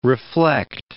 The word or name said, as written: reflect